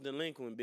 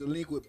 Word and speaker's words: Dlequint: Alright, before you go, we need you to say, it's pronounced Dlequint, bitch, and that's what we taking a shot to. – Alright, for sure. – Let's get it. – Hold on. – See your camera. – Yeah. It's pronounced Dlequint, bitch ass nigga.